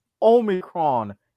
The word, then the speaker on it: Omicron: It's Omicron, as in Omicron Persei 8.